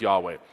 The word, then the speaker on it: Yahweh: But it's Yahweh. We think it's pronounced Yahweh. Jehovah is an attempt to translate this, but it's probably not a good attempt.